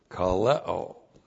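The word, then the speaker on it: Kaleo (καλέω): And then we have our verb, election verb, kaleo. K-A-L-E long O. Kaleo.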